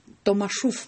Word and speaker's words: Томашов: Она называется «Тамашев», но знаменитая Томашов, по-моему, Томашов, так, да. Правильно? – А я вот с ударениями не точен.